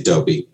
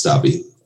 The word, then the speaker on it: Dobby: I always want to say Dobie, but um, I think it's Dobby.